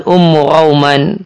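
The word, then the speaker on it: Umm Ruman: Umuruman ada yang membaca bidhommi ra, ra-nya di dhamma, inilah yang terkenal. Dan ada yang membacanya dengan memfatkah ra, ay rauman, ummu rauman.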